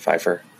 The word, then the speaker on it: Pfeiffer: Pfeiffer? – Yeah, that's how you pronounce it.